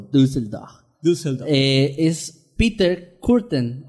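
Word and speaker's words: Peter Kürten: Pero es Peter Curtin y Dusseldorf y ya la chingada.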